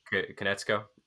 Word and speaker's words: Konietzko: By Nickelodeon, by Brian Konietzko. Is that how you pronounce his name, Konietzko. – Yeah. – Konietzko.